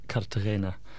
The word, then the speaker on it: Cartagena: So it's in these two sort of smallish cities in southern Spain called Murcia and Cartagena.